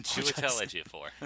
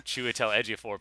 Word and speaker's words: Chiwetel Ejiofor: Chiwetel Ejiofor. – Chiwetel Ejiofor playing Scar, and he's just, you know, reading it dramatically, like, almost sleepwalking.